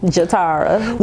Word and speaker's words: Je'Tara: Did I say that right? – You did say it right. – Yes. Thank you. I was giving myself brownie points for pronouncing people's names right. – You did it right. Most people don't. They call me everything but Jatara.